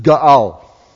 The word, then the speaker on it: ga'al: The word redeem in the Hebrew, because it's the Old Testament, is ga'al. That's how you say it.